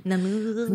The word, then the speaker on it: Namur: Namur. Namur. jag, tänker att, jag tänker, I mitt huvud uttalas det som lemur.